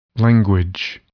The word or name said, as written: language